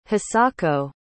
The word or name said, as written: hisako